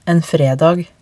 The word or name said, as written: en fredag